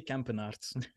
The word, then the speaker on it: Campenaerts: Did I say that right, Alfred? – Right enough for me. – Okay. – In Dutch we say Campenaerts.